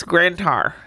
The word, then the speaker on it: Grand Tower: And you know, you said it wrong. It's Grand Tower.